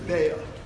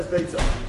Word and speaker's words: Beitza: However, the correct way to pronounce the name of the Masechta is Beitza.